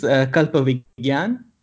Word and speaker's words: Kalpavigyan: It established Kalpavijan. I can't even pronounce it. I'm sorry. As the template for research Again. – in Indian future fiction. Can you talk about this? – Yeah, sure. Yeah, the word is Kalpavigyan.